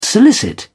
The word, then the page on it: solicit: British English